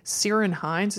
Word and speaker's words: Ciarán Hines: Ciarán Hines, is that how you say his name? – Yes.